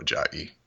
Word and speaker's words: Ajayi: I go Ajayi, but Ajayi. Well, he's actually British, so you'd pronounce it Jay Ajayi.